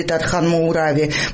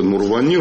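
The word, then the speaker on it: Тархан-Моурави: Тархан-Муурави. Правда, у Джопуа проблемы с произношением имени грузинского политика. – Есть заявление самого вот этого Мурвани, руководителя этой партии.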